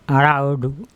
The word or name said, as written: araoDu Peje